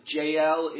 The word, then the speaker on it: Jael: Her name is, we pronounce it Jael in English, it's B-Ya-El, but Jael stands up.